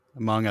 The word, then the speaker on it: Hmong: Probably for October, it's probably Mings or Hmong. Hmong, I think, is actually how you say it.